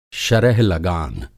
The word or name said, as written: शरह-लगान